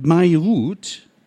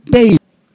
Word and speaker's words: Beirut: Hieß es seit Jahrhunderten Beirut, heißt es nun Beirut, ebenso wie Kuwait.